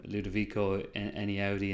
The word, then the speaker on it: Ludovico Einaudi: And when you have a track from Ludovico Einaudi in the background, good pronunciation.